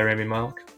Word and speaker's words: Rami Malek: Yeah, Rami Malek.